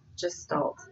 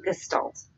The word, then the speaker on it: gestalt: you here, because it's not gestalt. – Gestalt? – It's gestalt.